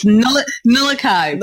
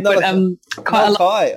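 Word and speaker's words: Nullaki: Completely way off, Brett. Sorry, it's Noolarkai. – Noolarkai. Oh, there you go. I wasn't even close. – Noolarkai. Quite a lot of the Aussies say it, Noolarkai.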